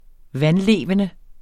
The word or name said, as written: vandlevende